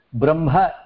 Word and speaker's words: ब्रह्म: प्रथमं हकारं लिखित्वा तदनन्तरं मकारः लिखामः किन्तु उच्चारणसमये ब्रह्म इत्येव उच्चारणं कुर्मः तत्र मकारः प्रथमं श्रूयते